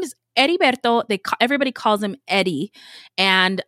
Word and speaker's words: Heriberto: So Heriberto, H in Spanish is silent. So when you say Heriberto, it sounds kind of like Eddie.